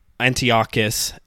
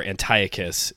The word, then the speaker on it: Antiochus: In the meantime, Antiochus, as you say in Hebrew, or Antiochus, if you go with the Greek pronunciation, he like desecrates the temple, just brings it down.